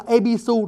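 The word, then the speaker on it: Ebisu (戎): Người Nhật cổ để cái tên ông này là Ebisu. Lạ lắm, viết chữ là viết chữ Nhung. Nhung y như chữ Nhung hồi nãy đó quý vị, như chữ Tây Nhung. Mà đọc âm là Ebisu, rồi từ từ mình có những cái bí mật ở đằng sau đó.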